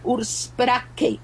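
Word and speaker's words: Ursprache: La palabra es Urspraca, y la palabra Urspraca, me informé, viene del alemán y se pronuncia Urspraca.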